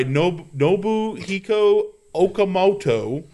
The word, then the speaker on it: Nobuhiko Okamoto: But by the way, yes, Katsuki Bakugo, voiced by Nobuhiko Okamoto.